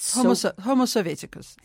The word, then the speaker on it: Homo Sovieticus: Homo Sovieticus.